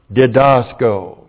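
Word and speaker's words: didasko (διδάσκω): Aorist active infinitive of the verb didasko. Didasko, D-I-D-A-S-K, long O.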